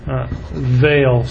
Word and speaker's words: Weil: And the chief credit officer for structured finance was Nicholas Vail, spelled W-E-I-L, and pronounced V-A-L-E.